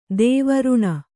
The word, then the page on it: ದೇವ ಋಣ: dēva řṇa